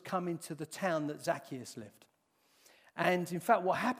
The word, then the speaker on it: Zacchaeus: Zacchaeus. Uh, that's how I pronounce his name. I know some people call him Zacchaeus or something like that. Zacchaeus, I call him.